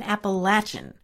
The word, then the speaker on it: Appalachian: Now, notice I said Southern Appalachian, which is the way you pronounce it in that part of the country.